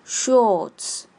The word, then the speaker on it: shorts: Shorts，注意 o 二的发音，o，跟老师念，o，o，shorts，shorts。